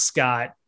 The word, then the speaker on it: Skjodt: Yeah, it's pronounced Scott. I can't believe you came up with that. – Simon Skjit. – It's the Simon Scott Arena. SKODJT. I think pronounced Scott.